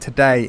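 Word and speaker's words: Tadej: It's like the word today in Cockney. Today. I looked it up. – Is it? – Today. Yeah, it is. Okay. The stress on the first syllable.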